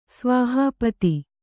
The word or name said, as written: ಸ್ವಾಹಾಪತಿ